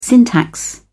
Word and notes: syntax: British English

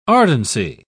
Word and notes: ardency: British English

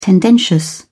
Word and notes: tendentious: British English

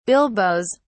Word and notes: bilboes: British English

